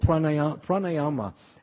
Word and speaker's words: pranayama: Are you talking of pranayama? Yeah, that's called pranayama.